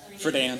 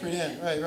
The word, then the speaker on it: Friedan: Friedan. – Friedan, right.